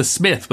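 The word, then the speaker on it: Psmith: The Blandings Castle, the Mr. Mulliner, the golf books, the Smith with a silent P,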